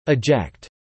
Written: eject

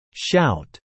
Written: shout

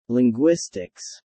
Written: Linguistics

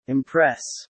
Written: impress

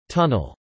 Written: tunnel

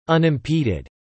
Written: unimpeded

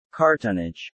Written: cartonnage

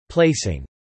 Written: Placing

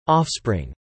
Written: offspring

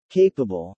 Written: capable

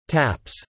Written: taps

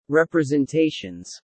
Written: representations

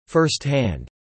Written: firsthand

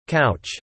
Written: couch

